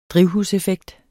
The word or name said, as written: drivhuseffekt